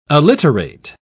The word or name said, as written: alliterate